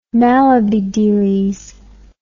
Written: mallabydeeleys

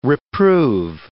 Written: reprove

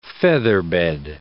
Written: featherbed

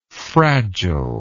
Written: fragileness

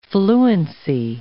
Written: fluency

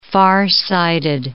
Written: farsighted

